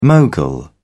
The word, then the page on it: mogul: British English